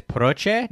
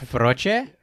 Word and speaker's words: proche: I think that's proche, not froche. But you, maybe the P is pronounced as F. I don't know.